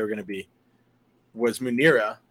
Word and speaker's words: Munirah: What, Muniro? – Munira. – How do you spell it? – M-U-N-I-R-A-H.